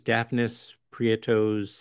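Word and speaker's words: Dafnis Prieto: Currently performs with Bernstein's Millennial Territory Orchestra, and I may mispronounce this, Daphnis Prieto's Sextet. – You got it.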